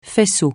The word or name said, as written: faisceau